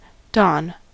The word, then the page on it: don: American English